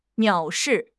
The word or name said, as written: 藐视